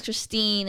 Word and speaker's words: Christine: Yo, yo, yo, my name's Christine and it rhymes with Listerine.